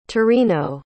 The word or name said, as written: torino